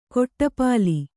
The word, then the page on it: ಕಡುಪೊಲ್ಲ: kannada; kaḍupolla